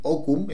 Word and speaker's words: Hokum: Se escribe H-O-K-U-M, Okum.